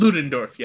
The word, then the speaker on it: Ludendorff: And Ludendorff, you know, is it Ludendorff? Am I pronouncing correctly? – Ludendorff, yeah.